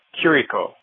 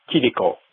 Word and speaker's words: Chirico: It's Kiriko. I know it's spelled C-H-I-R-I-C-O in English, but it's C-H as in Christ, not as in church. Look at the katakana spelling. It's Kiriko.